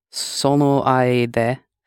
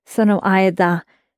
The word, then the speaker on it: sono aida: Sono aide. – And just be careful when you pronounce sono aida. – Aida. – It ends in da. It's not sono aide, it's sono aida.